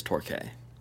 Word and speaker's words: Torquay: Torquay. Man, I'm going to make that mistake all episode long. – If it makes you feel any better, I doubt that anybody else knows how to pronounce it either. – And their local team, Torquay United FC. I'm pretty sure it's Torquay.